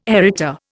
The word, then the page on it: Arita: Indian